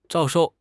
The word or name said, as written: 招收